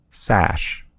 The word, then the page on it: sash: American English